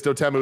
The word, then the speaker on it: Dotemu: But the one thing is Dotemu is pronounced dot emu, like the large bird.